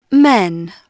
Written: men

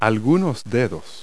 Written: fingers

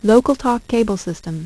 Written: LocalTalk Cable System

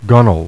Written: gunwale

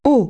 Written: high

